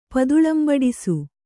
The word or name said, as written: ಪದುಳಂಬಡಿಸು